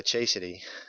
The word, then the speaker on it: chastity: Chastity. Well, you know, like you're chaste, okay? No, not chasing. Like, nope. Like a chaste person, somebody who's...